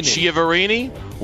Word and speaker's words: Chiaverini: Now wide receivers coach and assistant head coach Darren Chiaverini. I hope I said that right. – Chiaverini.